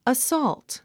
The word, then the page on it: assault: əsɔ́ːlt; アッソォゥト